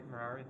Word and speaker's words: Merari: Is it pronounced Merari? – Yeah, Merari. – Merari, okay.